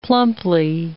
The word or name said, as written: plumply